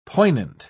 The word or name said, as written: poignant